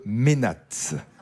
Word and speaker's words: mainate: c'est le ménate. Ouais. Okay. Vous ne le saviez pas? – Non. – Non, ça s'écrit M-A-I-N-A-T-E, le ménate ou ménate religieux.